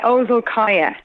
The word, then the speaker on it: Ozolkaja: It's Ozolkaja. – Ozolkaja, so don't pronounce the J.